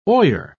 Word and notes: oyer: British English

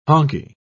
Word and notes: honkie: British English

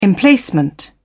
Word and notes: emplacement: British English

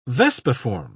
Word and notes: vespiform: British English

